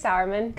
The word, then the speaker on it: Sauerman: And we have Mrs. Mary Grace Sauerman, hope I said that correctly, the Marketing Development Coordinator, and Mrs. Meredith Binkley, the Program Director here at Unless You. And at this point, I'd like to hand it over. Mrs. Mary Grace, if you would introduce yourself. – Hey, so my name is Mary Grace Sauerman. Good job pronouncing that.